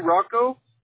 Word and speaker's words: Roco: R-O-C-O. – R-O-C-O. Okay, perfect. Is it Roco or Rocco? How do you say it? – We say Rocco. If Rocco. – You say Rocco, I would understand too.